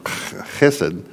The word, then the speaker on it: chesed: And if you're going to say it right, you got to do it with the phlegm. You got chesed.